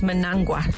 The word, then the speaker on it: Mnangagwa: And you're talking about President Emmerson Mnangagwa. – Emmerson Mnangagwa. – His surname is, I think, pronounced Mnangagwa. Karen Alberton. – The crocodile president, Emmerson Mnangagwa.